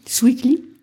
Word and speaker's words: Swikly: Si on veut aller plus loin sur ça, on peut s'adosser de certaines solutions qui existent comme Sweekly, c'est une solution de caution. – Sweekly ? – Voilà, c'est ça. Et ça s'écrit S-W-I-K-L-Y. Mm-hmm.